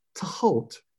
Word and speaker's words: Thoth: So with the theta, that brings to mind Yehuti and the modern spelling Thoth, and the pronunciation could be Tehut or something like that. Tehut. – Yeah, I mean, and if I was to say that in kind of a clipped pronunciation, you can see that the Greek spelling is doing something as long as you realize that that T followed by an H is there. So it's Tehut.